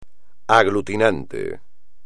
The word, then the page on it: aglutinante: espagnol